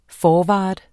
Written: forward

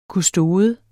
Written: kustode

